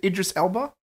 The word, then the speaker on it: Idris Elba: Is that how you pronounce his name? Is it Idris Elba? – Yes, that's right.